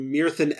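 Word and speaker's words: Myrddin: Which is pronounced something closer to Mirthin Emrys.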